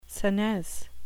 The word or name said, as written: senesce